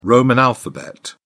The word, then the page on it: roman alphabet: British English